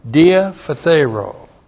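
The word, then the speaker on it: Diaphthero (διαφθείρω): Looks like that in the Greek, D-I-A-P-H-T-H-E-I-R, long O. Diaphthero.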